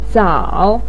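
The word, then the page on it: zao: tone 3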